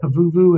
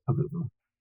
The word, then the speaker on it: Pavuvu: You say it like I do, by the way. I've always, all my life, said Pavuvu, and many of the veterans I've talked to say it like that. You will hear some people say Pavuvu. I just say Pavuvu.